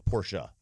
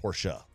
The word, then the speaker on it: Porsche: Porsche, as my neighbors told me to pronounce it properly. – Mm. – Porsche.